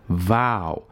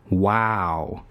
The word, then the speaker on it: vow: 这个誓言 vow 就念成了 wow，好 wow 很精彩，这个 wow，所以啊，一定要注意这个呜和呜的区别。